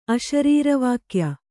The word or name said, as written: ಅಶರೀರವಾಕ್ಯ